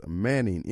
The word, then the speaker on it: Moenning: And it's pronounced Manning, M-O-E-N-N-I-N-G. And their web presence is manningenterprises.me. Not .com, but .me. Manning Enterprises. Manning Enterprises, M-O-E-N-N-I-N-G, enterprises.me.